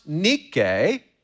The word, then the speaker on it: Nike: Now, when the Apostle Paul wrote in Romans 8:37, under the inspiration of the Holy Spirit, that we are more than conquerors, he used the Greek word Nike. It's pronounced Nike in Greek, and it means victory.